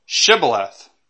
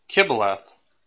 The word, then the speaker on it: Shibboleth: And if he said, "Nay," then said they to him, "Say now Shibboleth," okay, or Kibboleth. And if he would say Kibboleth,